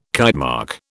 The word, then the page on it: Kitemark: British English